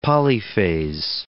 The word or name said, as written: polyphase